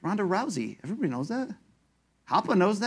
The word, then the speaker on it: Rousey: Call me crazy, but the way you pronounce the S in Ronda Rousey's last name is fucking weird. It's pronounced as if it was a S, but it really should be pronounced as if it was a Z, bro. Don't be that guy.